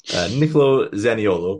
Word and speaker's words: Niccolò Zaniolo: Niccolò Zaniolo, pronounced it right this time.